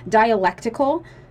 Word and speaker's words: dialectical: Dialectical.